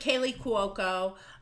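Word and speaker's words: Kaley Cuoco: Because at first it was like, And it's with Kaylee Cuoco from The Big Bang Theory.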